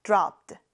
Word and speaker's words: dropped: 뒤에 ed가 붙어요. dropped. 뒤에 d 발음을 정확하게 해주셔야 합니다. 같이 해볼까요? dropped.